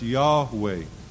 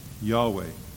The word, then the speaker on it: Yahweh: and so it's pronounced Yahweh. Yahweh.